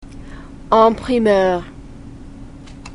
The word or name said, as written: en primeur